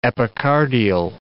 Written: epicardial

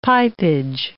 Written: pipage

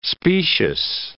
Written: specious

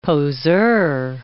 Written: poseur

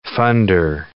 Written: funder

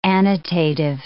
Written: annotative

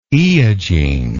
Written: eogene

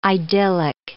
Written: idyllic

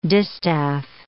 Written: distaff

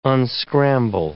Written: unscramble

